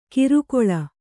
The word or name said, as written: ಕಿರುಕೊಳ